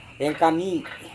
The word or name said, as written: rengkami